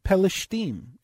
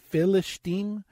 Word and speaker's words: Pelishtim: Pelishtim. And sometimes it would be pronounced Philistim or Palishtim, depending on the neighborhood you grew up in.